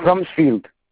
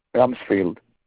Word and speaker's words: Rumsfield: It's Robert Rumsfeld. – Can you spell it? – It's RUMSFIELD. Rumsfeld, right? – Rumsfeld.